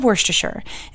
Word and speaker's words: Worcestershire: It might be optional and it might not, the Worcestershire, but I love Worcestershire, and yes, I'm pronouncing it correctly today.